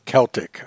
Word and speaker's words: Celtic: Celtic. Okay. Looks like it's a C, but it sounds like a K.